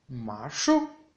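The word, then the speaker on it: ma shu (马术): Y por último, equitación en chino se dice ma shu, ma shu, ma shu, con un tercer tono en la de ma y un cuarto tono en la U de shu.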